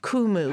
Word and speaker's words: Kumu: You said the app is called Kumu? – Kumu. – How do you spell that? – K-U-M-U.